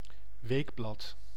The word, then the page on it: weekly: American English; /ˈwiːk.li/; /ˈwik.li/